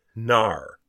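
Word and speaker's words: Knarr: And they say that according to Wikipedia, the K is silent. – Ah, so it is in fact Gnar.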